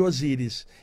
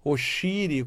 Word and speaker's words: Osíris: Esse é um dos mantras do grupo Os Amigos de Osíris. E a pronúncia Osíris é uma pronúncia aportuguesada aqui, né? Oxiri, como o outro chamaria, Oxi, e daí por diante.